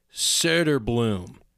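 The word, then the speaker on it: Söderblom: But so the umlaut over the O is pronounced like Turbo Triforce says like E-R in her. So it's like right? So it's Söder-blom.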